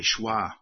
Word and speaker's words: schwa: The word schwa is part of linguistics. It's the shortest vowel sound, like the E in delay. That's called a schwa.